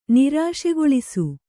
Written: ನಿರಾಶೆಗೊಳಿಸು

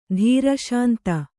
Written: ಧೀರಶಾಂತ

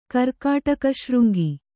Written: ಕರ್ಕಾಟಕಶೃಂಗಿ

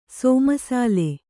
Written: ಸಮಸಲ್

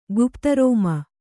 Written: ಗುಪ್ತರೋಮ

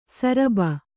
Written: ಶರಭ

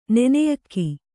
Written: ನೆನೆಯಕ್ಕಿ

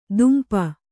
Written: ದುಂಪ

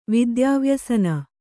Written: ವಿದ್ಯಾವ್ಯಸನ

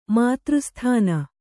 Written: ಮಾತೃಸ್ಥಾನ

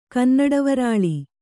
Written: ಕನ್ನಡವರಾಳಿ

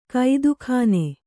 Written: ಕುದಿಗಣ್ಣು

